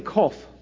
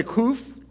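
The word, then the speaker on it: qof: Everybody say kof. Kof. You can say kuf or kof. It's pronounced either way.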